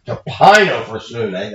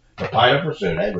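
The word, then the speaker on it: Tapinophrosune (ταπεινοφροσύνη): Tapiphronus. Tapinophrosune, that's it. I do know the word, but I couldn't come up with the pronunciation. Tapinophrosune, which is humility of mind, and prautes, which is gentleness, and then macrothumia, which is long-suffering.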